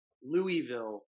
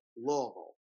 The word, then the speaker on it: Louisville: Y'all literally pronounce the town Louisville, Louisville. – Like, Louisville. Say like you've had a lot of bourbon to drink and you got a lot of cotton balls in your mouth.